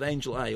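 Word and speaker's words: Angel-A: I mentioned that back-to-back with Angel A, or Angela, depending on how you want to pronounce it, which is the new film by Luc Besson, which it means completely different things.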